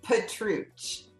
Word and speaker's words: Petruch: Petruch.